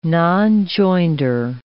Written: nonjoinder

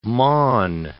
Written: maun

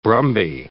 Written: brumby

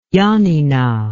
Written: yanina

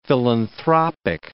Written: philanthropic

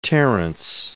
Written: Terence

